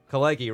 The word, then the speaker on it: Kalegi: Tumbled a little Kalegi. Bit, but I was Kalegi, right? That's yes. how you say it.